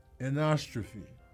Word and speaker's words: anastrophe: The word is anastrophe. – You got this. I hope you mess up so bad. – Anastrophe. – Say it once more. – Anastrophe. – Correct.